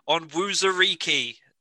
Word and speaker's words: Onwuzurike: The Bills select Levi Onwuzurike. Have I said that right? – Yeah, interior defensive line of Washington.